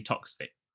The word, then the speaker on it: Toksvig: Sandy Toksvig.